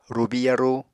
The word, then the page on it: rubi yarú: Apatani